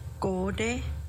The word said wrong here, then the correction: gonde: And going back to eel, gonde. – Gonde.